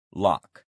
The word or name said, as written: lock